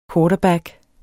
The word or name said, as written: quarterback